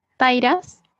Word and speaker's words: Tyras: A ver, en Facebook y en Instagram nos pueden encontrar como Tairas Store, que se escribe T-Y-R-A-S Store, tanto en Facebook como en Instagram.